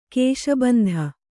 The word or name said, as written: ಕಾಶಿಬಿಂದು